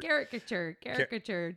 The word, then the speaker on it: caricature: Caricature drawing. – How do you say it? – Almost like carrot.